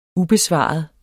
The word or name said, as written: ubesvaret